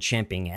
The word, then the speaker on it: champing: Well, Bill, you know, I'd like to apologize for Michael's chomping rather than champing at the bit.